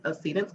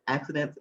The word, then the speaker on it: Accedence: And accede has a meaning, you know, for me, and people call it accidents. It's not pronounced accident.